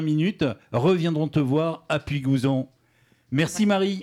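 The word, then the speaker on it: Puygouzon: Je joue pour une association qui s'appelle Ramène ta chaise à Puy-Gouzon, si je ne me trompe pas. Est-ce que je l'ai bien prononcé? – Tu l'as bien dit, c'est dans le Tarn et c'est des gens très sympas qui s'en occupent.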